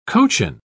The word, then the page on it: cochin: British English